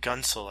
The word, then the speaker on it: Guentzel: Jake Gunsel. What do you think? Gunsel, I believe, is how you pronounce Gunsel.